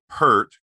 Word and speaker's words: Peart: And of course, Nick, Jason Segel's character, responds that, "Well, that's Neil Peart." – Right. – That's the way I always used to pronounce his name too, but it's actually Peart.